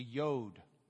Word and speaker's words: yod: And after that, you're going to memorize the yod. The yod. Yeah. Not Yoda. Just yod.